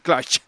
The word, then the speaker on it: Clutch: O Clutch. ¿Cómo se dice? – Clutch. – Muy bien.